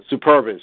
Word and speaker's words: Superbas: Like, there was one guy who did actually play for the Super Bras, as it was, or I don't know how you pronounce it. – Superba, I think it was. – Superbas, yeah. And so, right, because they were superb, I guess.